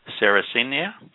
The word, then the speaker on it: Sarracenia: Sarracenia, S-A-R-R-A-C-E-N-I-A, and then purpurea, the purple.